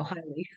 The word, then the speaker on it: HYLEE: Yeah, yes, HYLEE Capital. It's my son Leo and my daughter Hypatia. Their names combined. So there you go, HYLEE.